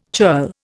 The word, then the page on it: churl: Indian